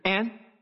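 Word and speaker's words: en (ἐν): 因为在希腊文 to all 这个词 to all 其实是 en，希腊文没有这个字，我只是说英文的发音就是 en，而 en 大多时候会被翻译成 in，也可以是 to。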